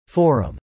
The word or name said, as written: Forum